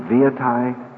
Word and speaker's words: Venetie: We'll hear argument now, number 96, 1577, Alaska versus the native village of, is it Venetie? – Venetie. – How do they get that out of V-E-N-E-T-I-E? May I ask counsel for respondent, is the correct pronunciation of the native village of Venetie? – Venetie.